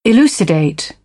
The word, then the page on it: elucidate: British English; ɪ